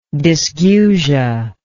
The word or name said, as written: dysgeusia